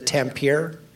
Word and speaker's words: Tampere: And now, you sent me a link, which I'll put in the show notes, of you guys at am I pronouncing it correctly, the Tampere Guitar Festival? – Yeah, that was one that I found on YouTube when you were asking me about concerts.